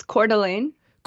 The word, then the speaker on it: Coeur d'Alene: Is it Cordial? Yeah. I'm unfamiliar with Idaho, honestly. How do you pronounce the city again? – It's Coeur d'Alene.